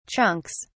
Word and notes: chunks: British English

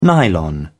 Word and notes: nylon: British English

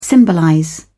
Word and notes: symbolize: British English

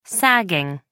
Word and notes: sagging: American English

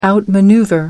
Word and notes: outmaneuver: British English

